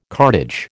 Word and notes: cartage: American English

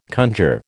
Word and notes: conjure: American English

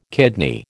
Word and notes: kidney: American English

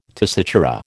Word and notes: tessitura: American English

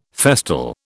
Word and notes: festal: British English

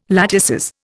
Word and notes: latices: Indian

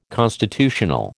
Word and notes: constitutional: American English

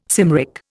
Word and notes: Cymric: Indian

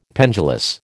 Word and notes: pendulous: American English

